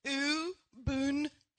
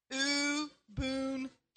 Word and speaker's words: Ubuntu: It originates from a Bantu word in Africa, and it's pronounced Ubuntu. Let's say that together. Ubuntu.